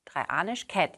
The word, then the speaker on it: Dreianischkat: In der erzgebirgischen Mundart wurde aus Dreifaltigkeit die Dreianischkat. Dreianischkat, jetzt habe ich es richtig ausgesprochen.